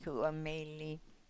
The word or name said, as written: ku o meli